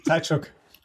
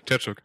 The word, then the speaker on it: Tkachuk: Tkachuk. – Tkachuk.